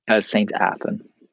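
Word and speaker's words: St Athan: St. Athan.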